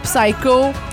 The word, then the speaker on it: Psycho: Tantôt, là, tu chialais qu'on, dans, mettons, Psycho, oui. – En anglais, on prononce pas le P.